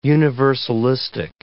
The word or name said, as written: universalistic